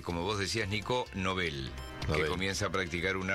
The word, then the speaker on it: Nobel: Nobel, eso es con B corta y es una palabra aguda que como termina en L no lleva tilde.